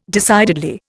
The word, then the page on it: decidedly: Indian